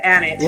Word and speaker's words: Annett: Is it Annett or Annett? – You nailed it. It's Annett.